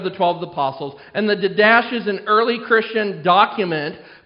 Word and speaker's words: Didache: I had my computer pronounce it for me last night because I knew I wasn't going to say D-I-D-A-C-H-E. Didache is what it... I think it said Didache. D-A-D-A-C-H-E.